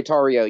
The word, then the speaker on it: Tarrio: You mentioned Mr. Is it Tarrio? Is that how you pronounce it? – Enrique Tarrio, yeah.